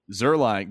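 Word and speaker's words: Zuerlein: But good old Greg, how do you say his last name? Zerloin? – Tenderloin. – Greg Tenderloin? Greg Zulerin? – Yeah, Zerline. – Zerline.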